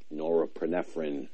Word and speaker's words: norepinephrine: The SSNRI works on the serotonin and the nor- norepinephrine is actually how it's pronounced.